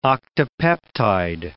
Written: octapeptide

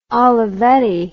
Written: olivetti

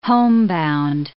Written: homebound

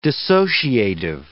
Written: dissociative

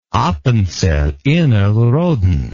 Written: Appenzell Innerrhoden

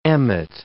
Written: emmet

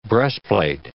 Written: breastplate

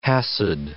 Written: Chassid

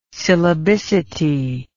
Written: syllabicity

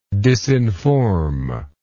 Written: disinform

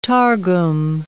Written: Targum

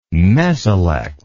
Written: mesolect

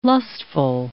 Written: lustful

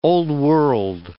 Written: old-world

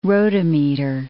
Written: rotameter

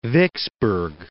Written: Vicksburg

